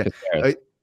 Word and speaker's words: Caceres: I mean, but I do think Caceres is that how you say his name? Alex Caceres? – Yeah. – Yeah. Caceres.